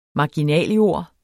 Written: marginaljord

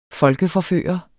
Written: folkeforfører

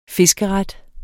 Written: fiskeret

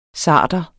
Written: sarder

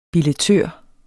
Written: billettør

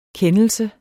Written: kendelse

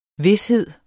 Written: vished

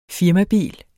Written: firmabil

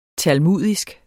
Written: talmudisk

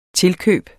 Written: tilkøb

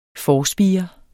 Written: forspire

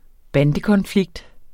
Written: bandekonflikt

